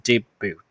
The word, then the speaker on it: debut: Okay, so Clean Bennett to make Taiwan debut. Debut 注意 T 不发音。 T 不发音，OK 哈，debut 中音在第二音节，所以这个我们通常会说呃，好，我们的惯用语哈，d b u t，不要念成 debut，是 debut，debut perform，OK，这叫初登场，呃，debut performance，performance 是表演的事，或者是你第一次，OK 哈，debut album。